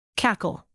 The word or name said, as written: cackle